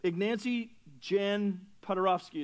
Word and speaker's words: Ignacy Jan Paderewski: Ignacy Jan Paderewski is how you pronounce it.